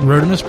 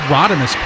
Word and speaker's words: Rodimus: Because I really appreciate it rhymes with Optimus. – Well, he is... Yeah. I mean, it's Rodimus Prime.